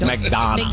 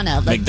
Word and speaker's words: McDonough: Is that how you say it? McDonough. – McDonough. Like D-O-N-N-A. – McDonough. Yeah.